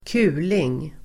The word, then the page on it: kuling: [²k'u:ling]